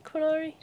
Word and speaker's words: Qunari: It's like, oh, we've got this many Qunari... how do you pronounce them again? Is it Qunari? – Yeah, Qunari.